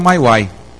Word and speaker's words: MIUI: Alguns dos filmes que você encontra, Cyanogen Mod e a MyWai, né, que para mim sempre foi MIUI, mas me disseram, me corrigiram outro dia, pronuncia-se MyWai.